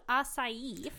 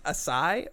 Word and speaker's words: açaí: Deborah asks, first of all, have you heard about açaí from Brazil? – Açaí, Jaquel.